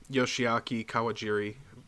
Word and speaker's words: Yoshiaki Kawajiri: Yoshiaki Kawajiri by name.